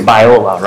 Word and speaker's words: Biola: So you went to the school was Biola, right? – Yeah.